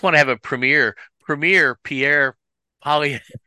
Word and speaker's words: Pierre Poilievre: Pierre Poivre? What's it? – Pierre Poilievre.